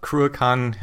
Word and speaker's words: Cruachan: So tell us a little bit about Cruachan Hill Press. Did I say it right that time? – Yeah, you did.